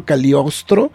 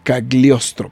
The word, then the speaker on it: Cagliostro: Mira, estoy viendo, eso es una serie de televisión, El Castillo Cagliostro, que es Cagliostro, perdón, que lo pronuncio bien.